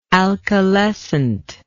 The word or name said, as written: alkalescent